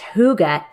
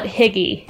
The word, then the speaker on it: hygge: Okay, I think it's actually like hygge with like a weird noise, but it says that it's pronounced hygge, H-O-O-G-A, but it's spelled H-Y-G-G-E, and it's like... – I always call it higgy.